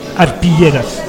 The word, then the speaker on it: arpilleras: Une des choses qui apporte beaucoup à ce film, ce sont les arpilleras, si je prononce bien. – C'est juste.